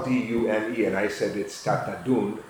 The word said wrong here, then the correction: Tatadune: and that's wrong. It's Tatadune.